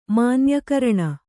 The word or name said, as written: ಮಾನ್ಯಕರಣ